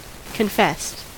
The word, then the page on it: confessed: American English